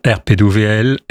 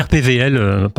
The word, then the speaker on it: RPWL: RPWL, RPVL. – W. – Ça s'écrit W, mais on dit RPVL. – Ah oui, parce qu'en allemand, le W ne se prononce pas, voilà.